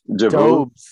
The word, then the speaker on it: Doubs: Dubs. – Dubs. That's how you say it, Dubs.